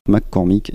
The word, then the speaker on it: McCormick: En tout cas, cette marque américaine, il s'agirait de Deering McCormick ou Cornick. – Alors on a de la marque Deering et on a des marques McCormick également, oui.